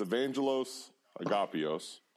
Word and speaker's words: Evangelos Agapios: All right, so it's Evangelos Agapios. – Evangelos Agapios, easy. – Easy, pretty much. It's a big old Greek name.